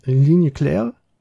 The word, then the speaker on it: Ligne Claire: Ne, der diesen, ich werde es wieder falsch aussprechen, Ligne Claire.